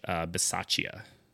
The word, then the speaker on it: Bisaccia: I'm pretty sure his name's pronounced Rich Bisaccia. – Sure. – Because it's, I would assume it's Italian.